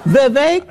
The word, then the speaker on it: Vivek: It is Vivek because I heard him do a rap and he said it rhymes with cake.